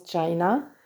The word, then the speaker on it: China: En anglais, ça se prononce China, comme la Chine.